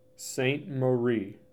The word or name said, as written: St. Mary